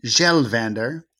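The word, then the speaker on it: Kjellvander: Okay, this one is Christian Gjelvander, and it's with a silent K.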